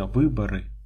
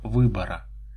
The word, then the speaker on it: выборы: Нужно, однако, не забывать произносить не «выборы», а «выбора», как и ряд других тонкостей, ибо они доказывают твою способность держать нос по ветру. Все прекрасно знают, что надо говорить «выборы», но вся партия говорит «выбора».